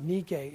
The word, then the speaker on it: Nike: By the way, they would pronounce it Nike in Greece today, but that's okay.